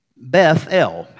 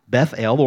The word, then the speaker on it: Bethel: So, house of God, Beth-El, or as we say, Bethel. Jacob names this place Bethel. And then Jacob and his family, they journey on from Bethel,